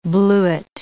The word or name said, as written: bluet